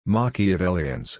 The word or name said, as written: Machiavellians